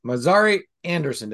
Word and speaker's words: Mazari-Andersen: Today, I have the pleasure of introducing uh, Alicia, Mar- I'm going to say this right, Mazari Andersen. Did I get that right? You got it right.